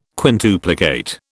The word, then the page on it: quintuplicate: British English